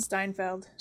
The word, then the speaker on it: Steinfeld: Steinfeld. – Like the pickles.